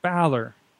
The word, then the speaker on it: Balor: Think of Valor, only with a B. – Valor. Balor. There you Balor.